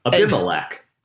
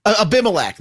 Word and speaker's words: Abimelech: Ambelakic. – Now you screwed me up. Abimelech. – Abimelech. There we go.